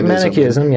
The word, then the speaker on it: Manichaeism: Both the Manichaeism, I'm saying it wrong. – That's correct. Manichaeism, yeah.